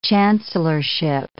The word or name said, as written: chancellorship